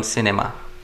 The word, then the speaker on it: cinema: For example, the term cinema. Si is short, ni is short, ma is supposed to be long. – Long, right. Cinema.